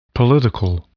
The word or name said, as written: political